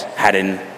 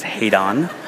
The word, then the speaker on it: Haddon: you should quote Charles Haddon Spurgeon. And I'm going to keep saying Spurgeon's middle name until people stop mispronouncing my son's name as Haydon.